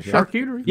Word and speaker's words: charcuterie: Charcuterie. It's like you bite the side of your tongue. – Charcuterie.